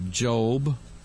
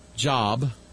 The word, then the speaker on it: Job: Job, or as some people pronounce, Job.